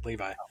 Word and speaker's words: Levi: Levi, yes, that's right, Levi.